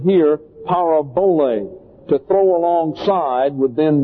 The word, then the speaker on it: parabole: It comes from the Greek word parabole, P-A-R-A-B-O-L-E, pronounced parabole. It is a compound word, para, P-A-R-A, means alongside, and bole, or balo is the actual vocabulary term, means to throw, like to throw a ball.